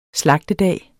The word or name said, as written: slagtedag